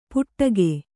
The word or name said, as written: ಪುಟ್ಟಗೆ